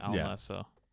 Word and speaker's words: Al Nassr: With Saudi Arabian club Al Nassr, I think is how you say it. – There's a lack of vowels. – Nassr. Yeah, Al Nassr. Is what everyone says.